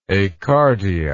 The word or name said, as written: acardiac